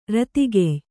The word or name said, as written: ರತಿಗೆಯ್